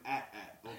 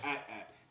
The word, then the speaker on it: AT-AT: It's called an AT-AT, okay? You go back and watch those old commercials. They pronounce George Lucas said AT-AT, not an AT-AT or ATS.